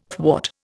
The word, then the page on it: thwart: Indian